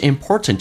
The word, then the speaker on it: important: 而且关键是 important 这个单词啊，除了最后一个 t 不发音，这叫略音，中间那个 t 也不发音，当 t 跟 n 在一起的时候，气流阻塞一下再到 n 这个发音叫 important，